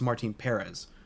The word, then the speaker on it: Martin Perez: Also, I don't know if you know this, but it's Martin Perez. Because us Rangers fans, for the first, like, 6 years of his career, pronounced his freaking name wrong.